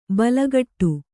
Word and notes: ಬಲಗಟ್ಟು: balagaṭṭu

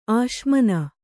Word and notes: ಅಸಮಾನ: asamāna

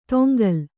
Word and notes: ತೊಂಗಲ್: toŋgal

